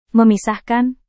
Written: memisahkan

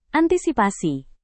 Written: antisipasi

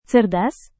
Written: cerdas

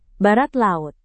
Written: barat laut

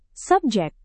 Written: subjek

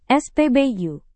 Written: spbu

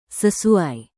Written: sesuai

